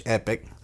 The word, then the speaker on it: Epoch: So if you're looking to get into the lithium space, and this company, E-P-O-C-H, Epic, Epoch, however you want to pronounce it, they're also American-based.